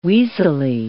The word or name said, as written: weasely